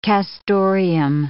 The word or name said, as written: castoreum